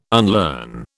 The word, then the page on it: unlearn: British English